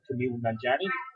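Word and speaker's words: Kumail Nanjiani: It stars a guy called Kamele Nanjiani, which I'm happy I pronounced the name right.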